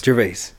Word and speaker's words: Gervais: Gervais.